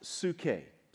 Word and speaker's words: psuche: This word, not sure if you realize it or not, but this word in the New Testament is pronounced psuche. Psuche. If you saw it, you would understand why it is the root of the word psyche. P-S-U-C-H-E is the psuche.